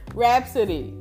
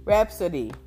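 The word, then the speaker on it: Rapsody: Rhapsody. – You hear Rhapsody, right? She's... Is that how you say her name? Oh my God. Rhapsody.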